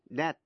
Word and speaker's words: debt: A-C-C-U-R-A-C-Y, accuracy. Và precision, P-R-E-C-I-S-I-O-N. Cũng ôn lại những chữ dễ phát âm sai như là chữ key, Q-U-A-Y, nghĩa là bến tàu. Debt, D-E-B-T, nghĩa là nợ. Và receipt, R-E-C-E-I-P-T, là biên lai.